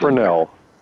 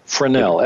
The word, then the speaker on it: Fresnel: And the first question I have to ask is, how do you pronounce the name of the lens that they put Fresnel. – Fresnel, F-E-R-S-N-E-L. – Correct. Named after Augustin Fresnel, the French physicist who invented it. – So what is a Fresnel lens?